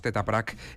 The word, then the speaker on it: Tetra Pak: Estas charlas de las que hablas se marcan en el proceso creativo Tetra Pak 2023 y se van a celebrar los tres primeros domingos de febrero. – Y está con nosotros Jone Estíbariz, que es la coordinadora de los encuentros Tetra Pak 2023.